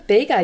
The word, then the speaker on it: Bega: Is this going towards Bega or Bega? I'm a terrible Englishman, so forgive my mispronunciation. – No, Bega, you got it right.